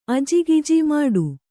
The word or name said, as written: ಮುಟ್ಟ